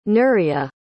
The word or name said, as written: nuria